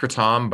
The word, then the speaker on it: kratom: Like with Kratom, it's pronounced Kratom, but I just like saying Kratom more. So for everyone who's like, cool. Oh, Kratom, I know, but I'm just saying Kratom because it's easier.